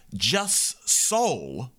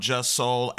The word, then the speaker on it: Jussol: Chicago, that last song you heard by Just Soul, J-U-S-S-O-L,